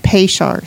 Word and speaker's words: Peshar: Peshar, see? It's like an S-H.